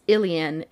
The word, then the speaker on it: Aelian: Most commonly known as Ilian. It's spelled like alien, but like A-E-L-I-A-N. That's pronounced Ilian.